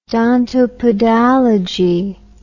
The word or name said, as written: dontopedalogy